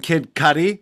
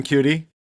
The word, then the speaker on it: Cudi: And of course, we have to mention Kid Cudi. That's how you said it's pronounced, even though I want to call him Cutie.